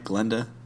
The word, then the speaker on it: Glinda: And, it's Glinda, right? Am I saying that right? Glinda? – Glinda, with an I, I'm pretty sure.